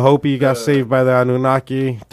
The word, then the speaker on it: Anunnaki: About the Anunnaki, Anunnasi? Am I saying it right? – Anunnaki.